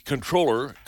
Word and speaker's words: comptroller: The comptroller, you pronounce the P in that word? – Comptroller, yeah.